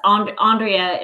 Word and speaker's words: Aundrea: I answer to all variations. Andrea is typically Andrea.